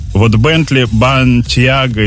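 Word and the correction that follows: Bentayga: и Бантайго. – Бентайго. – Бентайго.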